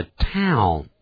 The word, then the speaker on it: town: 你记不记得我们说过那个 town, 那个小镇呢？ He lives in town. 他住在镇上 T-O-W-N T-O-W-N 这个元音的发音跟 wow 一样的 town He lives in town 咱们中国人呢会念成 town He lives in town 他被 town 到了这可不是 town 而是 town wow 而不是 wow Wow, she is beautiful.